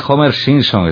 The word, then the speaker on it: Homer Simpson: Después viene Homer Simpson, que también estaría bien pronunciado, ¿no? – Sí. – Homer sí. – Simpson.